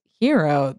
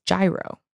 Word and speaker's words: gyro: But I do feel like a lot of people do not pronounce it as hero. They pronounce it as gyro.